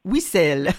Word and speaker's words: Whistle: C'est comme ça que vous prononcez Whistle? – Whistle.